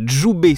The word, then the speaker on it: Jubai: Alors Djoubé, ça s'écrit J-U-B-A-I. Swimwear, S-W-I-M-W-E-A-R.